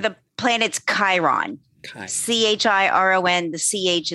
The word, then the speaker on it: Chiron: When you look at the minor planet slash comet, because it does have two designations in astronomy, it's pronounced Chiron, as if the C CH was a K.